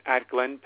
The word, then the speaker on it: Atglen: cloudless night in Atglen, Pennsylvania, and that's spelled A-T-G-L-E-N, Atglen, Pennsylvania. Cloudless night, zero wind.